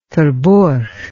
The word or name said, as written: terborch